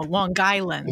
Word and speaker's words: Long Island: No, Long Island. You have to say, emphasize on guy.